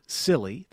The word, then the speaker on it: Sylly: The app is called Silly, that's spelled S-Y-L-L-Y,